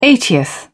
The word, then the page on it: eightieth: British English